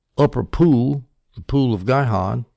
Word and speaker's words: Gihon: The upper pool was the pool fed by the spring of Gihon, G-I-H-O-N. Sometimes it's pronounced Jihon, apparently, but I'm going to call it Gihon or Jihon, Gihon.